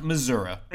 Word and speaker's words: Missouri: Because it's I live in misery. All, it's not every day like I that. Live in misery. – It's pronounced Missouri.